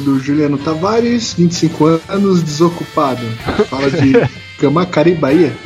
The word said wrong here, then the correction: Camaçari: Eu pronunciei certo, será? – Camacari, eu acho que é Camaçari, né, não? – Cara, temos fãs na Bahia.